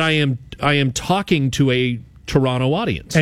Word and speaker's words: Toronto: I said, you do realize that I'm a Toronto, I pronounced the second T.